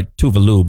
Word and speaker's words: Tove Lo: Then you got Tove Lo. Technically, she goes by Tuvalu, but no one pronounces it right.